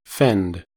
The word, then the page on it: fend: American English